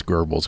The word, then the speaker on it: Goebbels: With my limited background in the German language, I would say it's Goebbels, because there's an umlaut in there.